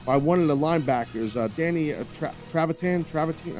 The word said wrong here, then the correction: Trevathan: I might be saying his name wrong. – Trevathan. From the Bears. – How am I saying it? – Trevathan. – Trevathan.